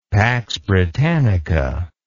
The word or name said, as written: pax britannica